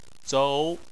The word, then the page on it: zou: 2